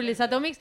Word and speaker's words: Les Atomiques: Facebook Les Atomics. Se escribe Les Atomiques.